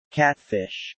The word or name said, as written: Catfish